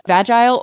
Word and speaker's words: vagile: And so it's spelled just like it sounds, V-A-G-I-L-I-T-Y. It comes from the noun, or I'm sorry, the adjective is vagile or vagil. You know, depending on your, like, both of them are accepted pronunciations.